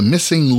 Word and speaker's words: MSing: Dr. Gretchen is the founder and creator of The Missing Link, and missing in this case is spelled M-S-I-N-G.